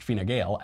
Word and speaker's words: Fine Gael: The Irish Conservative government, Fine Gael, pronounced Fine Gael, after years of mounting pressure in Ireland, has announced a vote on repealing Article 8 of our Constitution.